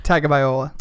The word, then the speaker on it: Tagovailoa: They absolutely killed Ole Miss with the QB tandem, although it looked like whatever the hell Tagovailoa, is that how you say it? – Close. Tagovailoa.